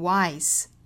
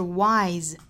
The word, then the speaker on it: wise: wise 不是读 wise，而是 wise。